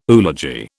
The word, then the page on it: oology: British English